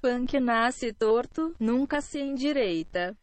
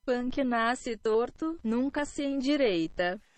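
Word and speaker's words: Punk nasce torto, nunca se endireita: Here is an actual pronunciation in Portuguese. – Punk Nace Torto Nunca Se Endireita.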